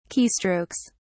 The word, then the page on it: keystrokes: British English